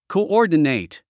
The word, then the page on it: coordinate: kouɔ́ːrdənèit; コウオーディネイト